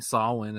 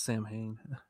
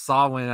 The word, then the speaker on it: Samhain: I still find it hilarious how he keeps mispronouncing Sawin as Sam Hain. Halloween two made me think that's exactly how you pronounced it. So for years, I kept saying Sam Hain. – That's because it's that's the logical way to pronounce it. – It's a logical way to pronounce it. It's Sawin. I was just like, I'm, I'm with John Carpenter now.